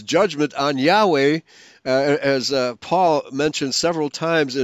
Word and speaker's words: Yahweh: You pronounce it, that J in English, as Yah, just as you would pronounce Yahweh. Okay, and then go ahead. – Exactly in the same way as it's pronounced in Hebrew.